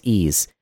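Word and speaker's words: Ys: Y-S, apparently it's pronounced Ease.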